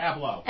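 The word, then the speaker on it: Ablow: How do you say his name? Dr. Keith Ablo? – Ablo, yeah.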